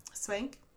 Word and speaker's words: Swank: Swank, é, pronunciei certo.